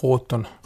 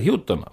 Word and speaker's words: Houghton: Head raadiokuulajad, ei tule siia Israel Houghton, vaid tuleb hoopis Israel Houghton, aga laulud põhimõtteliselt on samad.